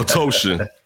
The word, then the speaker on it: Otoshin: Rhymes with lotion. I always teach people that. Otoshin.